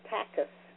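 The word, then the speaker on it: takis (ταχύς): The word swift in the verse in the Greek language, which is pronounced takis,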